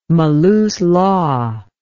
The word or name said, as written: maluslaw